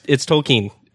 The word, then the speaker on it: Tolkien: Is it Tolkien? Tolkien? – Yes. Okay. Yeah, no, it's Tolkien.